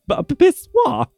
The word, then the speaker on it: pissoir: Is that how you pronounce it? A pissoir or pissoir? Pissoir.